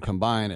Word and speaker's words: combine: What do you think about, now that the combine is done, and of course in football we put the emphasis on the second syllable. It's not offense, defense, and combine. It's offense, defense, combine. It's an underwear Olympics thing.